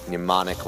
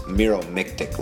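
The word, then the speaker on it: Meromictic: It's pronounced Miromictic Lake, and it really is green.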